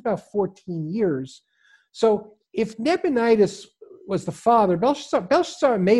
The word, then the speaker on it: Nabonidus: His father was king, and it really only a couple of years after the king became, and that was Nabonidus, you got his name right.